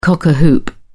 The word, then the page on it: cock-a-hoop: British English